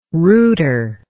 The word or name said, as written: rooter